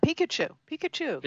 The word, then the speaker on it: Pikachu: Also, from the on the movie side, if you got kids, you might want to check out the Pokemon Detective Pikachu. Hopefully, I'm pronouncing that correctly. – Pikachu.